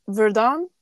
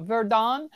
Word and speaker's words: Verdun: Verdun. – Yeah, Verdun.